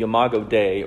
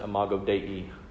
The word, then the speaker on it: Imago Dei: The Latin term is the Imago Dei, or as some pronounce it, Imago Dei.